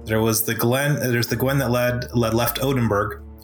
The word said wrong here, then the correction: Wotenburg: Wotenburg, and sorry, said it wrong.